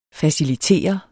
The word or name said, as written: facilitere